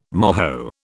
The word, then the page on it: moho: British English